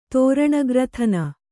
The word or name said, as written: ತೋರಣಗ್ರಥನ